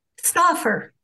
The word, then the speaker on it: Stauffer: Is it Stauffer? Is that how you pronounce it, Susan? – Stauffer. S-T-A-U-F-F-E-R. Yes, Stauffer.